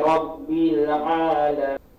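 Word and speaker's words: rabbil alamin (رَبِّ الْعَالَمِينَ): Hati-hati di sini dalam pengucapan rabbil alamin, jangan ada pantulan di huruf lam ya. Jangan ada pantul bin. Coba sekali lagi. – Alhamdulillahi rabbil alamin.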